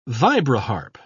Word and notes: vibraharp: British English

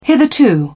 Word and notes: hitherto: British English

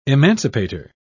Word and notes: emancipator: British English

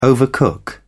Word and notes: overcook: British English

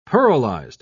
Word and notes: pearlized: British English

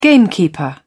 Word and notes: gamekeeper: British English

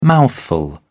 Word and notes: mouthful: British English

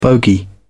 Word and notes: bogy: British English

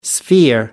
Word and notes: sphere: American English

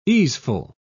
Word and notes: easeful: British English

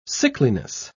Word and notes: sickliness: British English

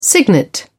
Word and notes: cygnet: British English

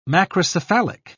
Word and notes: macrocephalic: British English

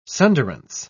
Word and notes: sunderance: British English

